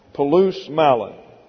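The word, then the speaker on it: polus mallon: That's P-O-L-U-S plus M-A-L-L-O-N, pronounced palus malon.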